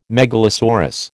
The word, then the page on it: megalosaurus: American English